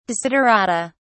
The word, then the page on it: desiderata: British English